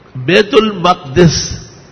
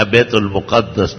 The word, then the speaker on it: بیت المقدس: بیت المقدس یا بیت المقدس دونوں طرح تلفظ صحیح.